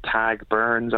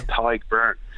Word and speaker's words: Tadhg Beirne: Everyone calls him Tag Burns over here. – Oh, really? – So Tag Burns. I'm thinking, who the hell's Tag Burns? Then I realise I'm Tyke Byrne.